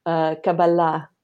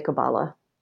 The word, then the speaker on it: Kabbalah: If you're in Israel, you would say Kabbalah, but here we say Kabbalah.